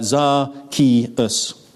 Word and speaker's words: Zacchaeus: Its origin is Greek Greek variant of the Hebrew name Zakei, and he's generally pronounced uh, Zacchaeus. So Zacchaeus, or Zacchaeus, circumstances were corrupted.